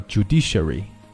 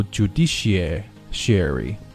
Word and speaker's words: judiciary: 这个词英音的读法读作 judiciary, 它读美音读法读 judiciary, 它有一个 ad 音在这儿 ,OK,